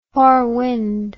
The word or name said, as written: forewind